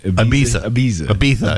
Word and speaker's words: Ibiza: I won a game in Wilkes-Barre, and I took a pill in Ibiza, or whatever. What is it? How do you say it? – Ibiza.